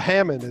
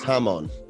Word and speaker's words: Hamon: The Hammond is bugging me. Isn't it Hamon? – It's Hamon. – I think it Hammond, is Hamon.